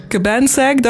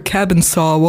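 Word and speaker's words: Cabansag: Cabansack, daar gaan we het bij houden. – Cabansack. – Ik dacht dat het eerder zo cabin saw was of iets dergelijks. – Cabansack. – Cabansack.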